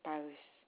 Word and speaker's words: Parisse: My last name is Paris. – P-A-R-I-S? – S-S-E. – Okay, spell that again. – P-A-R-I-S-S-E. – P-A-R-I-S-S-E? – Yes.